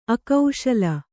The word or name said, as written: ಅಕ್ಕಸಾಲ